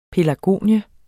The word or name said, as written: pelargonie